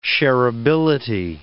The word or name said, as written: shareability